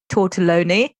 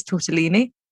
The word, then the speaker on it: tortellini: Oh my god, the other day I got corrected because my whole life I've been saying tortelloni. Apparently it's tortellini. – Obviously you knew it's tortellini.